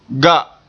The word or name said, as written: ga